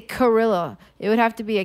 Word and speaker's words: Cirilla: Cirilla. – Oh. Hmm? – Cirilla. – It's got a fucking C in it. – Yeah, C that's pronounced like an S in this case. Cirilla.